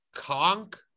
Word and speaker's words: conch: It's spelled K-O-N-C-H, I believe, but it's pronounced conch. Conch is what it's called.